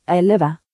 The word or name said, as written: 11